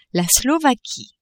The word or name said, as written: Slovaquie